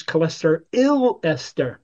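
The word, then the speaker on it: cholesteryl: If you listen to my pronunciations, cholesterol ester, YL, not OL. Esters have YL, is the biochemistry abbreviation.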